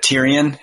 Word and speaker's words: Tyrion: But Tyrion, how do you say his name? – Yeah, that's right.